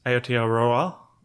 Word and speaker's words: Aotearoa: Aotearoa. – Okay. Yep. Which is? – New Zealand. – We are New Zealand. – Maori. – Correct. – Mm-hmm. – Is it Ao or is it Aotearoa? – Aotearoa.